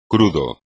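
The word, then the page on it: crudo: español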